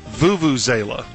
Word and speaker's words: Vuvuzela: The term she's trying to come up with is Vuvuzela. She had a different pronunciation.